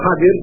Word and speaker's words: Khidr: Khidr did certain things which Musa didn't understand, may God's peace and blessing be upon them. And then Al-Khidr, or Khadir, is the actual proper pronunciation of his name, explained to Prophet Musa alayhi salam what was behind what he did.